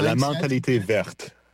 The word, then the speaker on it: La Mentalité Verte: La Mentalité Verte.